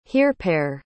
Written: hearpere

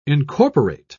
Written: incorporate

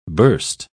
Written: burst

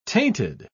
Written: tainted

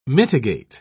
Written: mitigate

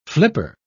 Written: flipper

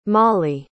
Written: mali

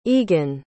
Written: egon

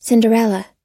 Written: cinderella

